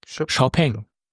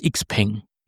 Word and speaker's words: XPeng: Nee, sag mir nur, wie spricht man es aus? – Xiaopeng. Okay. So hat es mir jedenfalls der Kollege von Xiaopeng erklärt. Gut. Hört sich auch schöner an als Xpeng.